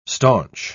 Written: staunch

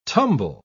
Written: tumble

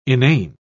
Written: inane

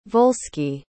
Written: volsci

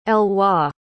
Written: Elois

